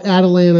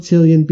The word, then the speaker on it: Attilan: They call it Attilan. I call it Attilian because I learned to pronounce it by reading, not by hearing it.